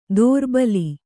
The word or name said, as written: ದೋರ್ಬಲಿ